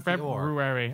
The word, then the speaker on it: February: Don't forget to pronounce February. – The R. There's an O in February.